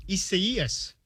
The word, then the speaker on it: Isaias: The correct pronunciation of the hurricane, it's Hurricane Isaias.